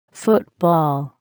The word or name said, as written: football